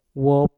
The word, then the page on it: wop: mpur